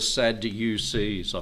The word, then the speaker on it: Sadducees: These are the Sadducees. I pronounce it like that because they were a sad lot.